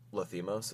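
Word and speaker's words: Lanthimos: And there's some brutality in, like, a lot of, Lathimos, is that how you pronounce his last name? – Yep.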